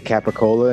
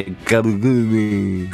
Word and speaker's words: capicola: They pronounce all the letters. – They say Capicola in Italy? – Yeah. I'm sure they say it cooler than that, but... They don't say gabagool.